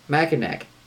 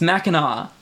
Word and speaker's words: Mackinac: So this one, Mackinac. How do you pronounce it? – Mackinac. – Nope. – I know it's something else. I can't remember what it is. – It's Mackinac.